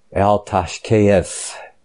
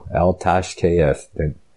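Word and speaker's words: Al-taschith: Psalm 58, to the chief musician, Al-Tashkeith. That's phonetically pronounced correct in Hebrew. Al-Tashkeith.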